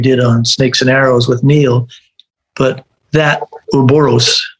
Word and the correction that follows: Ouroboros: is that correct pronunciation? Yeah, Ouroboros.